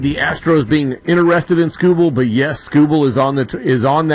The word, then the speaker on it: Skubal: Okay, and Skubal, is that how you pronounce his name? Yeah, Tarik Skubal.